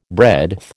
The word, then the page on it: breadth: American English